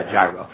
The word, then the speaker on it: gyro: Gyro. It's pronounced Hero, but yeah, Gyro.